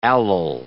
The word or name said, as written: allyl